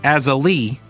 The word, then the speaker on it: Azalea: Jennifer says her grandma's name was Azalea, but it was pronounced Azalee or Azalee, Azalee, but it was spelled like Azalea.